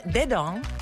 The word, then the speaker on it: Dedans: It's called Dedong, which means inside, and it's by Azaria and MNJ.